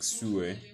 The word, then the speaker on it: Sulwe: I don't know how to pronounce it, but then the spelling is Sulwe, like Sue. That's how she pronounced it.